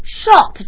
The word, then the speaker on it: short: 那么 short 这个词比较有意思啊它虽然是表示短的但是它的发音却是长的 all。